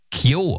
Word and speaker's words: Keo: Ich glaube, sie werden Keo ausgesprochen, obwohl sie K-E-O, also anders geschrieben werden, als man es denkt, aber leitet sich vom Nachnamen. Ist egal.